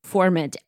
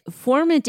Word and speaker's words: Furmint: To say Furmint. Furmint is Furmint. Like ferment, yeah.